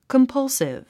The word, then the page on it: compulsive: kəmpʌ́lsiv; カンパルスィブ